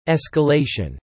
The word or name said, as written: escalation